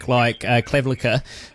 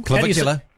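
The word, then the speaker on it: Clavicular: I get the misunderstanding or the agenda from some interviewers when they look at a guy like Clavicula. I've said that wrong. – Clavicula.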